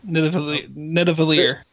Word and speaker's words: Nidavellir: So Rocket and Groot accompany Thor to, I don't know how to pronounce this, but Nidavellir? Nidavellir? – Yeah, that's it. Nidavellir.